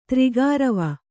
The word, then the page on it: ತ್ರಿಗಾರವ: tri gārava